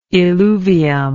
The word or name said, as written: illuvium